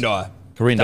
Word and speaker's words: Quirindi: Quirindi. That's a hard word to pronounce, but it is Quirindi.